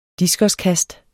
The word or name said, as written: diskoskast